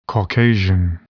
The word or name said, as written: Caucasian